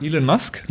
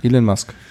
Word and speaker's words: Elon Musk: Der Tesla, Alan Musk. – Elon Musk? – Elon Musk, genau.